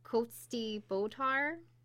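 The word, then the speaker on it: Cóiste Bodhar: And it's known as the Coach a Bower. I think I'm saying that right. – Okay. – Which comes from the Irish, I'm not going to say this right, Coatsdy Botar. – Yeah, that's right.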